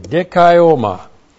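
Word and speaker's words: dikaioma (δικαίωμα): Looks like that in the Greek, D-I-K-A-I, long O-M-A, dikaioma.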